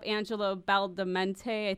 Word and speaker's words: Angelo Badalamenti: It's the same reason I love Angelo Badalamenti.